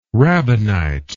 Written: rabbinite

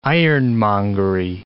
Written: ironmongery

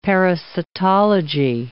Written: parasitology

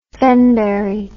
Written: fenberry

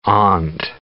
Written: amn't

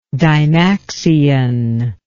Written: dymaxion

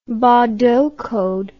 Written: baudot code

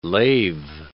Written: lave

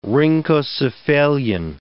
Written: rhynchocephalian

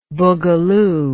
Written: bugaloo

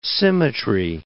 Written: symmetry

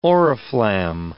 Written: oriflamme

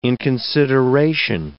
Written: inconsideration

